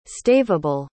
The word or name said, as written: staveable